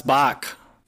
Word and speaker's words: Bach: Is it B-A-C-H? – Yes. – Yeah. Seal Box. – I feel like that's Bach. – Yeah, that's Bach.